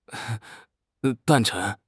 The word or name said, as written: 诞辰